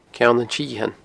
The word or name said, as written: ceannantsithein